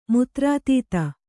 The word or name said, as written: ಮುತ್ರಾತೀತ